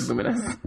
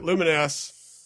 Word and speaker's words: Lumines: Is that how you say it, by the way? – I thought it was Lumines. – I don't know. – I've always heard Lumines. – All right, Lumines.